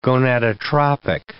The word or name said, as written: gonadotropic